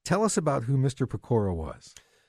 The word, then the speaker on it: Pecora: There's this fellow by the name of, I'm not even sure I'm going to pronounce this correctly, Joe, Ferdinand, is it Pecora? – Pecora, yep.